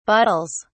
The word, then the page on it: bottles: British English